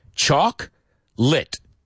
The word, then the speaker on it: chocolate: So it's, in English, it's two syllables, this word. Dos sílabas. That's it. Choc-lit. That's it. Chocolate. Not chocolate, not chocolate.